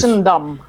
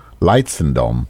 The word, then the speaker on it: Leidschendam: But you're from a town, I can't pronounce, according to the Drag Race. – Leidschendam. – Leidschendam. So where's that? – Leidschendam. Leidschendam. It's near Den Haag.